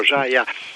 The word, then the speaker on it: Zaja: Si pronuncia in italiano Zaja, e vuol dire desiderio in croato Ciacavo.